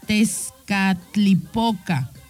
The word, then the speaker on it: Tezcatlipoca: Te casticopla. Ay, no, yo creo que lo dije mal, ¿no? Tezcatlipoca. Ahí está ya.